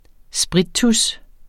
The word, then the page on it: sprittus: -ˌtus